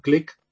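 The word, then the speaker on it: Glick (גליק): So, last week, I think we used the word glick. Was that the right word? Glick. – Correct. – So this week, we're going to use the opposite of glick, which is Unglück.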